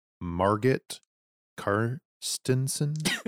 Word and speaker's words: Margit Carstensen: So I loved Margit Carstensen. – Perfect pronunciation.